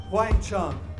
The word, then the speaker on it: Wang Chung: we're called Wang Chung.